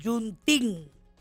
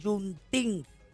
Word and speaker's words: Juneteenth: Ok, Juneteenth, así se pronuncia, Juneteenth.